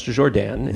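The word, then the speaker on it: Jourdan: Or pronounced Jordan, if you pronounce it correctly.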